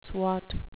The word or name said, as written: swot